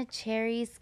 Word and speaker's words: Chairez: That's my last name is spelled C-H-A-I-R-E-Z Comedy. So you guys can find me there. I always have to spell out my last name because it's weird. And then I say cherries and they think cherry on top.